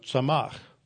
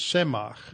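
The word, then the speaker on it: samach (צמח): And notice it's spelled with a tzayin, a mem, and a chet. So samach. You just change your vowel points a little bit, and it's semach. It's the same word.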